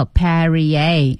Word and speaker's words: Perrier: 注意读音叫 Perrier.